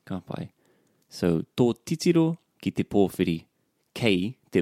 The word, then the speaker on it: titiro: Just with that word, titiro, whenever we have this double up in the two first syllables, papaki, titiro, pupuhi. So you got the two first syllables in a word of three syllables. The stress is on the middle one.